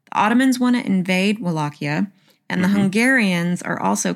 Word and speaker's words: Wallachia: Am I saying that wrong again? – You're using like an S sound. – Wallachia? – Wallachia.